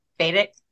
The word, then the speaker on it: Vedic: Am I saying that right? Vedic. – Mm-hmm.